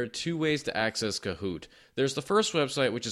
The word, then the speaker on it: Kahoot: And uh, Kahoot is spelled K A H O O T, like Kahoot, like a like an owl, like hooting.